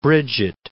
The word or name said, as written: Bridget